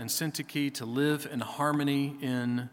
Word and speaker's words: Syntyche: Now, that's how I researched linguistically how to say their names. If you want to say it some other way, have at it. I'm fine with it. Rock on. But I'm going to go with Euodia and Syntyche.